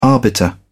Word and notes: arbiter: British English

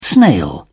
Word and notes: snail: British English

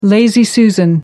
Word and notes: lazy susan: American English

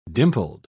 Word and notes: dimpled: British English

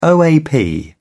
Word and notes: OAP: British English

